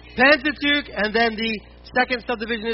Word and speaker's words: Pentateuch: Yeah, and if you said it right, you should have spit on the person in front of you. Pentateuch.